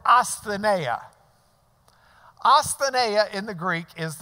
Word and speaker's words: Asthenia: T-H-E. The V-looking shape, the new, is an N. And then E-I is just I-A in the way we pronounce it. Asthenia.